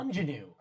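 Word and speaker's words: Aunjanue: Jesse Buckley for The Lost Daughter, Judi Dench for Belfast, Kirsten Dunst for Power of the Dog, and Anjouane Ellis for Anjanou. You say it like the word.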